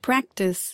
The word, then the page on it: practise: American English